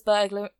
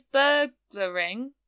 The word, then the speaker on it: burgling: Oh, I can't say it without adding another syllable. – I'm going to keep this in. This is hilarious. Burglary. – He was burglary. – Doing a burglary. – He was burgling. Burgling. I'm adding another. I'm adding a syllable in there that shouldn't happen.